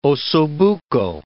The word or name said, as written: osso bucco